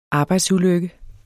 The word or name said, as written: arbejdsulykke